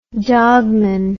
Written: dogman

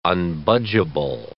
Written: unbudgeable

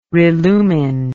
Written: relumine

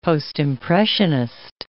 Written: Postimpressionist

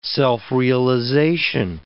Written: self-realization

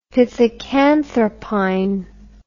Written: pithecanthropine

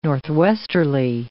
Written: northwesterly

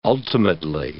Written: ultimately

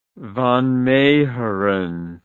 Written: Van Meegeren

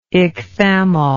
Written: ichthammol

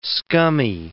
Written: scummy

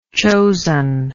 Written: chosenness

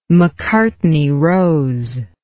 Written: macartney rose

